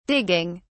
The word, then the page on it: digging: British English